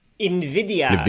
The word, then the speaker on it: NVIDIA: NVIDIA. It's like the Spanish NVIDIA. Word for envy, which is why their logo is a green eye.